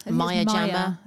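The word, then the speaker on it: Maya Jama: I'm all about Maya Jammer. Is that how you say her name? Maya Jammer? – Maya Jammer? – Maya Jammer.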